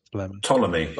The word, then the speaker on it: Ptolemy: Oh, and his full name, Professor Richard Rowell Ptolemy Powell. – Mm. – Like, you know, like one of the Egyptian pharaohs. – Ptolemy. – Oh, Ptolemy, is that how you pronounce that?